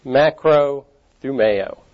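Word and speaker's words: makrothumeo (μακροθυμέω): Patience, as it occurs in our theme verse, is the Greek makrothumeo. That's a correct pronunciation.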